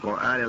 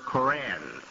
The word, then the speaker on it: Quran: The Westerners tend to pronounce that word Koran, K-O-R-A-N, Koran. You find it in the dictionaries. You might find the correct spelling now, the preferred spelling in the Muslim community, Q-U-R-A-N.